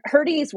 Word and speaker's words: Hrdy: So I love Herdy's work is fantastic. It's spelled H-R-D-Y for those of you who are looking for her work.